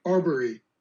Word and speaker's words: Arbery: With Albury, if I'm saying the name right, I hope I am. – Arbery. Aubrey.